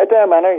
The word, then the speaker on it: Adare Manor: Yeah, Adare Manor, yeah.